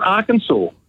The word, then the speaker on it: Arkansas: So Kansas is pronounced Kansas, but the one next door, Arkansas, is pronounced Arkansas.